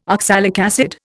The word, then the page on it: oxalic acid: Indian